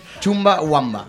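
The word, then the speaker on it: Chumbawamba: Y el tema es de Chumba Wamba.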